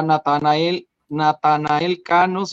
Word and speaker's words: Natanael Cano: porque estrenó su tema junto a Natanael Cano, si lo dije bien. – Sí.